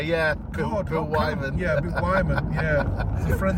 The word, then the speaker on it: Wyman: Also, uh, was uh, Bill uh, uh, Wyman. Wyman. That's how I pronounce it.